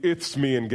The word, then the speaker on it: Isthmian: the image of the Isthmian Games. Now, I said Isthmian, and it sounds like I have a lisp, but that's actually the way it's pronounced, because Corinth was built on what's called an isthmus.